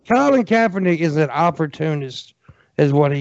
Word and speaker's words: Colin Kaepernick: You're saying his name wrong. It's Colin Kaepernick.